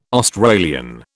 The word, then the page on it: Australian: British English